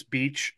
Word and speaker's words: Beach: So, like, when people figure out that my last name is Beach and it's spelled like, you know, the beach,